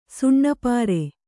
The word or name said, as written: ಸುಣ್ಣಪಾರೆ